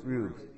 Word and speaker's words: Rube: pronounce Rube. Not Ruby, but Rube.